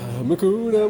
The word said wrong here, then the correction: Hakuna: Is it Makuna or Hakuna? – Hakuna. – Oh, Hakuna. God, I've been saying it wrong for 30 years.